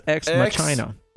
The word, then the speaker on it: ex Machina: It's actually pronounced ex Machina.